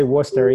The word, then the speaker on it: Worcester: which is funny that I, you know, I know to say Worcester, even though I always argue that it's not pronounced that way. I'm like, it's not spelt Worcester. – Yeah, it's not even close to spelled that way.